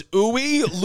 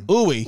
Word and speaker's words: Uwe: His name was Uwe Ludwig Horn. – Yeah. – Or maybe it's you. – You Ludwig Horn. – Uwe. – Uwe. It's spelled U-W-E.